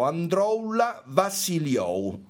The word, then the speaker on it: Androulla Vassiliou: Androulla Vassiliou.